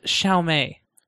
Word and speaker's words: Xiaomi: It's pronounced Xiaomi.